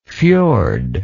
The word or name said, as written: fjordic